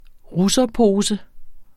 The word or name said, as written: russerpose